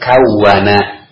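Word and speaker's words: kawwana: Kawin ini ya berasal dari kawana. Ya, tasdid. Wawunya jangan tidak ditasdid. Ditasdid. Kawana.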